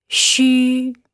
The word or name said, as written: xu